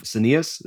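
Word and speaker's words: scenius: You know, in the book, you have this great quote from Brian Eno when you're talking about the concept of senius. Is that how you pronounce it? – Senius. – Senius. – Genius versus senius.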